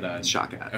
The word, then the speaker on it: Shawkat: And I know, obviously, there were some other actors in the movie that have been in other things, like Elia Shawkat, also... I'm not sure how to pronounce her last name, but Shawkat.